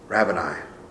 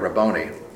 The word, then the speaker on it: Rabboni: And she turned and said to him, Aramaic, "Rabboni." Sorry, Rabboni. That's how you should pronounce it.